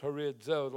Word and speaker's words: horizo: Declared is a Greek word, horizo. Horizo. It's pronounced horizo, like it has a D in it, because the Z sound in Greek has a TZ sound.